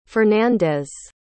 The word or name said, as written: fernandez